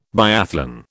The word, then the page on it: biathlon: British English